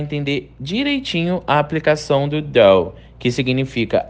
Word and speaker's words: though: A pronúncia do though é com a língua entre os dentes, fazendo um som de D. Though, though, ok?